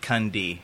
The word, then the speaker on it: Kunde: But in our tongue, we pronounce it Kunde.